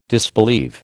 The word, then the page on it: disbelieve: American English